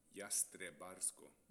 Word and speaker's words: Jastrebarsko: det är nog mycket konsonanter Jastrobarsko. Ah, var det så? Ja. – Du, förmodligen har du rätt. Ska vi se på uttalet bara. Jastrebarsko.